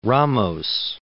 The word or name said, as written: Ramos